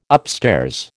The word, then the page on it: upstairs: American English